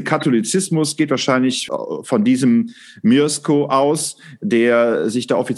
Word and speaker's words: Mieszko: Also ich meine, ich habe mich natürlich jetzt auch für diese Sendung mal ein bisschen kundig gemacht, ob das jetzt Mieszko, wird der Mieszko ausgesprochen, 966, der quasi sich nach römisch-katholischem Ritus taufen lassen hat. – Ist auf jeden Fall M-I-E, Mieszko.